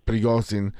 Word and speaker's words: Prigozhin: E come la leggi questa, queste dichiarazioni di un, di un personaggio, insomma, di, di, un certo rilievo come Prizogin che ehm, Prigozhin che eh, si, si, pronuncia in, in, questa, in, questa, maniera.